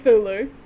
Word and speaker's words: Cthulhu: It's this strategy board game that, around the world fill with Chinolith? Shit. How do you say it? – Spell it for me. – Cthulhu. – No, okay. – Cthulhu.